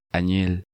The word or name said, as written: Anil